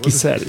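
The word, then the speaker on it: Güisel: Es que es G-U y Gisel. – Ah, sí. Gisel. Y no Gisel. – No porque no tiene diéresis.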